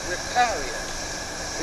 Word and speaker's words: vicarius: It's a transliteration of the Latin word vicarius, V-I-C-A-R-I-U-S. The Latin word vicarious. See, in Latin, the V's are pronounced like W's.